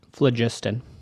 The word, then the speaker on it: phlogiston: Am I pronouncing that right? Phlogiston? – Phlogiston.